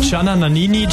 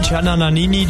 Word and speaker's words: Gianna Nannini: Gianna Nannini, Gianna Nannini, Gianna Nannini.